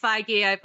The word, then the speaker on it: Feige: Is it Feige? – Yeah. – Because that's how I've always pronounced it. And then one time I heard something else and... All right. – I'm sure it's... Yeah. – So it's Feige.